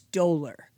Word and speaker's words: dolor: Well, I think I might have pronounced, I said dollar. I believe it's dolor.